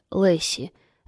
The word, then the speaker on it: Лейси: Она имеет в виду, что ее имя произносится Лесси, а пишется Лейси.